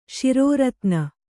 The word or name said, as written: ಶಿರೋರತ್ನ